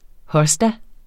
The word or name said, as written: hosta